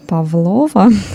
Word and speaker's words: Павлова: И также самый знаменитый теперь десерт Павлова с ударением на О.